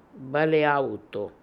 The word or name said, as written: Baleaooto